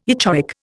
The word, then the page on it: echoic: Indian